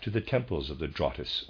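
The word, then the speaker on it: Drottes: The spiritual life of Europe emanated from a mother lodge in Scandinavia, Drottis Lodge, and that's, readers aside, that's spelled D-R-O-T-T-E-S. I am pronouncing that Drottis.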